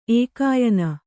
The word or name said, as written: ಏಕಾಯನ